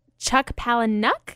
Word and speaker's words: Chuck Palahniuk: Chuck Palahniuk.